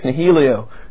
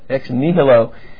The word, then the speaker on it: ex nihilo: So first, God created the universe out of nothing, ex nihilo. I can't pronounce that Latin right. I was asking is that, how do you say it? – Nihilo. – Ex nihilo.